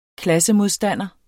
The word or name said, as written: klassemodstander